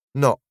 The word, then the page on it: not: British English